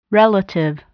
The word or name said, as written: relative